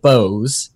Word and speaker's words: Boaz: Not to get ahead of myself, this month we're reading The Libertarian Mind, A Manifesto for Freedom by David Bowes. It drives me crazy that he pronounces it Bowes, because it is like how you spell Boaz in the Bible. But apparently he likes it pronounced Bowes.